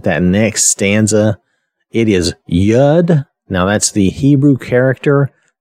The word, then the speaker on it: Yod: It's spelled Y-O-D, so we might be tempted to say Yod or Yod, but from what I could look up and tell, it's pronounced Yud.